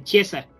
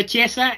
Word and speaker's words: Chiesa: Ahora sí lo dije bien, Chiesa. ¿O dije Chisea. Ok, perdón. Respondió Chiesa, este jugador que me canso de decir que sigan en este eh, eh, en este podcast que lo sigan detenidamente.